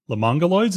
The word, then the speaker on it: Lemonguloids: Lemonguloids? Is that how it's pronounced? No? Yes? Yes, Joe? Yes, okay.